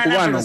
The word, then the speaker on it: Cuban: Se escribe Cuban, como si fuera Cuban, como cubano.